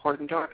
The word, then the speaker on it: Hardendorf: And for everybody who just joined us, um, this is Mr. Timothy uh, Hardendorf.